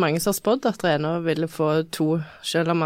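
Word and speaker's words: Renaa: Vi har fått to stjerner til Renå, eller Renault, jeg vet ikke hvordan det uttales det. Renå. ja. – ja. Eller Rænå! Han er jo trønder. Det er trøndersk, ikke fransk.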